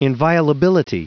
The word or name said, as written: inviolability